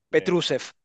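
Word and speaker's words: Petrušev: Μάθε να τον λες. Πετρούσεφ.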